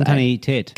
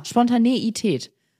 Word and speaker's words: Spontaneität: Ich bin mir ziemlich sicher, dass das Wort so ausgesprochen wird wie bei Koffein und nicht wie bei Frühstücksei. – Spontaneität. Genau, Spontaneität.